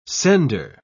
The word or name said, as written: sender